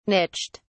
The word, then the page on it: niched: British English